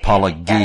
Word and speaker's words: apologia: And, of course, your father is always ribbing or insulting Pastor Jeff about mispronouncing apologia. He says right. – It's apologia.